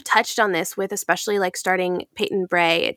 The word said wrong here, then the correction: Bre: Did I pronounce that right? – Bree. Okay, yes. Peyton Bre.